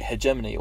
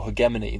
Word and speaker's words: hegemony: The first one is the word white hegemony or hegemony, both mm-hmm. are acceptable pronunciations.